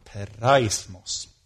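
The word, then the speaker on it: Peraismos (πειρασμός): Peraismos. Är det bra uttal, Kalle? Härligt.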